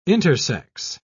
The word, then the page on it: intersex: British English